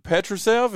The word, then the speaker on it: Petrušev: Tucker, and Filip Petrušev. Is that how you say his name? – Yes, yes.